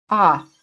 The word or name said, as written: OFF